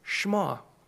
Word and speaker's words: Shema: And then my other passage is the Deuteronomy 6, 4 through 9, which is, I always called it the Shema, but I did that little thing where you plug it into Google and you say, how do I say this? And apparently it's Shema. So I think Shema sounds cooler, but Shema is more accurate.